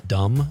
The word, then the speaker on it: dumb: John in Madison says, words with silent letters bug me too. Pneumonia? Dumb?